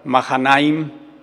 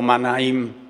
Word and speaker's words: Mahanaim: Por eso llamaron a aquel lugar Mahanaim o Manaim, como lo quieran eh, eh, pronunciar.